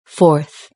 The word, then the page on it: fourth: British English